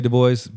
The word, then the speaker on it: Du Bois: I lean towards the French pronunciation, as I do with my own last name, which is why I say Du Bois.